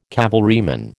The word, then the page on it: cavalryman: American English